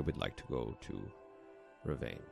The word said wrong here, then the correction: Revan: Did I say it wrong? Fuck me. – Is there... – You mean Revan? – Revan, oh, motherfucker. I'd Revan. Like to go. I'd like to go to Revan.